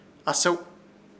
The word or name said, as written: ASEW